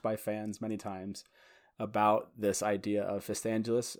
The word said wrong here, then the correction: Fistandantilus: Uh, fist, how do you pronounce it? Fist, fist, fistandulus or fistandalus? – Fistandantilus. There we go.